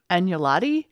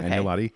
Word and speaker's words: agnolotti: Agnolotti. – That sounds good to me. Okay. Agnolotti.